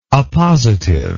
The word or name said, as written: appositively